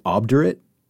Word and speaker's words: obdurate: Is that like obdurate? O-B-D-U-R-A-T-E? – My phonetics say A-H-B. Obdurate. – Okay. – But it is not phonetically. It is O-B-D.